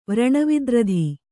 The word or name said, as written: ವ್ರಣವಿದ್ರಧಿ